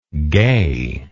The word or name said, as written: gayness